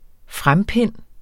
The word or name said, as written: frempind